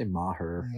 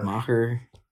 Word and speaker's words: Maher: or how do you say his name? – They say Maher. – Maher.